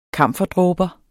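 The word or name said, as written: kamferdråber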